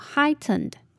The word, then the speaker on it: heightened: sim。那这里的 P 我们轻轻带过就好，那后面的 O 呢则是发 e 的音，念作 symptoms。接着我们看到 heightened，这里的 E-I 我们要发 I 的音， G-H 不发音，那后面的 E-N 则是发鼻音 N，最后的 E-D 则是轻轻的带过的的音，念作 heightened。